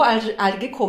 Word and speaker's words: Algeco: Was genau Algeco macht, beziehungsweise Algeco, das ist eigentlich die richtige Aussprache, denn ursprünglich ist es ein französisches Unternehmen und deswegen die französische Aussprache.